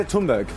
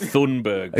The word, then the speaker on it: Thunberg: My mother was Greta Thunberg. – Yes! Well, that is extraordinary. That is unbelievable. – I think it's pronounced Thunberg.